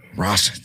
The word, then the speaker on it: Russett: I'm terrible at pronouncing last names, but I'm going to say Finn Rosset. Uh, Russett. – Russett.